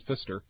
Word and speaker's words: Pfister: It's spelled P-F-I-S-T-E-R, and I'm staying there next week. The Hotel Fister.